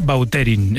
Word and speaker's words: Vautrin: Es que a mí me suena mejor Bauterín. Es como... – Da más fuerza, ¿no? – Más puede repetir más la pronunciación. – Elegante. – La original francesa, Vautrin.